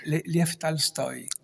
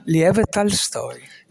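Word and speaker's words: Lev Tolstoj: quello scrittore Leon Tolstoi, no? – Lev Tolstoi. – Ecco, come si pronuncia? – Lev Tolstoi.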